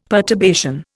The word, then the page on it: perturbation: Indian